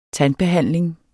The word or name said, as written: tandbehandling